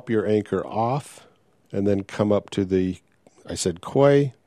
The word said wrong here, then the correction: quay: I've been corrected. That's key. I wish they'd just spell it key instead of Q-U-A-Y, which to me says quay.